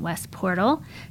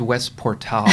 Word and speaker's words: West Portal: My other favorite is Siren for clothes in West Portal. Check that out. – I'm pretty sure it's West Portal. – Oh, my God. You've never pronounced that neighborhood correctly.